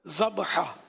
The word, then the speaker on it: ذبح: ذبح لفظ جو ہے نا, ذبح معنی جو جانور ذبح ہوا, اور وہ جو جس کو ہم ذبح بولتے ہیں نا یہ ذبح نہیں ہے, یہ ذبح اصل تلفظ ہے ذبح.